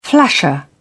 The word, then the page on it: flasher: British English